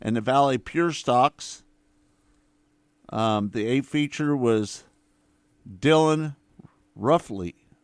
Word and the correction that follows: Rafferty: How do you say that, Kurt? Do you see that? – I'm not looking at it right now, so I can't tell you. – R-A-F-F-U-R-T-Y. – Rafferty. – Rafferty.